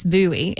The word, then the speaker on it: Bowie: I always want to say Bowie because when you look at it, it looks like that, but it's Bowie.